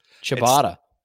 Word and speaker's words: ciabatta: So my number four, which you're going to hate, I'm sure, but and I don't even know how to say it correctly. Ciabatta? – Ciabatta. – It's ciabatta, yes. Thank you.